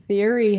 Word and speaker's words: Thierry: The answer is Thierry Henry, but it's spelled T-H-I-E-R-R-Y.